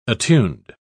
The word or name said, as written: attuned